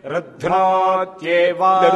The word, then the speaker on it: ருத்னோத்யேவ: ருத்னோத்யேவா. ருத்னோத்யேவாங்கிறது கரெக்டான ப்ரொனன்சியேஷன். ஆந்திராவில் கொஞ்சம் தட்டுக்கிடலாக போய் அர்த்தனோத்ய, இந்த கரெக்டான உச்சரிப்பு தமிழ்நாடு அடுத்தபடி கர்நாடகத்தில் இருக்கு. இந்த வடக்க போனால் கேட்கவே வேண்டாம், அது ஒருதுன்னு சார் இருக்கு.